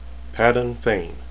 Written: fain

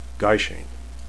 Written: gaishain

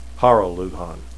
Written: haral

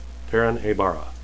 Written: perrin

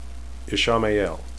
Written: ishamael

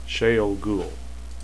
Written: shayol

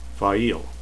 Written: faile

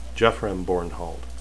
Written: geofram

